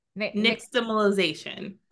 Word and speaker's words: nixtamalization: Nixtamalization.